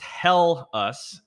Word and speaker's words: Helles: Helles is pronounced "hell us" is the immensely, I'm probably pronouncing this wrong.